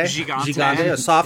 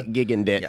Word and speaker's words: Gigandet: Gigante. – Gigante. A soft G to start it off. To match the soft bum that we see as walking past us in the Gigandet.